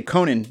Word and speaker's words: Conan: No, I usually say Conan, like O'Brien, you know? – Yes. – Conan O'Barbarian. That's a good one. – But that's not how it's pronounced, correct? It's Conan? – Yeah, pretty sure it's Conan.